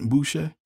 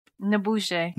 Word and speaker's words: N'Bushe: I thought that was how do you say it? Boucher. – N'Bushe.